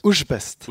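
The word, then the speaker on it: Újpest: Uypest. Åssen snakker du, hvordan uttaler du det som dere kritiserer meg for uttalen min her? – Ushpest. Ushpest ja.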